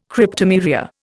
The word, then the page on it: Cryptomeria: Indian